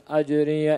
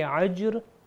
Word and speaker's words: أجر: اس آیت کی پھر سے تکرار کریے مگر خیال رکھیے کہ یہ جو اجر ہے یہ الف سے ہے تو اس کا عین کا تلفظ نہ ہے. اس کو اس طرح کریں گے وَمَا أَسْأَلُكُمْ عَلَيْهِ مِنْ أَجْرِ. اجر اگر کریں گے عجر تو اس کا مطلب ہے عین سے تلاوت کیا,